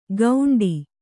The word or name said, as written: ಗೌಂಡಿ